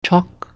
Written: Chauk

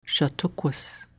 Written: Shattookquis

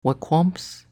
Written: Wequamps

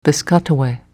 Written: Piscataway

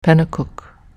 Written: Pennacook

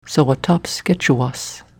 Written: Sawwatapsketchuwas

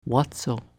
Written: Watso

